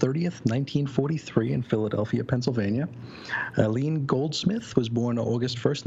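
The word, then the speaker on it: Aline: We have Robert Crumb and, how did we say this, Aileen or A-Line? – Aileen. – Aileen.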